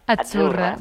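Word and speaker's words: Azzurra: La Z no es la Z como decimos en catalán tan sonora, no. Es achurra. – Muy bonito. – Es como pizza.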